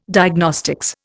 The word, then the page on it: Diagnostics: Indian